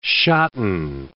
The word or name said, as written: shotten